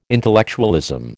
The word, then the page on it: intellectualism: American English